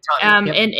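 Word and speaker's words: Qahtani: Katani, is that how you say them? Their last name, Katani? – Yeah, Katani.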